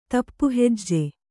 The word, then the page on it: ದಾಪುಹೆಜ್ಜೆ: dāpu hejje